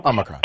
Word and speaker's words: Omicron: Probably the best way to pronounce it is Omicron, as if the O were A-W-E. – Omicron.